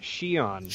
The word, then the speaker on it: Xian: We also have the Twi'lek, the purple Twi'lek, named Yeon, I think is how you pronounce it, although it's like spelt X-I-A-N. – They pronounced it Xion.